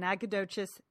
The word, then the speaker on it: Nacogdoches: She's going to an author festival in Nacogdoches, Texas. Did I say that right? – Yes, you did.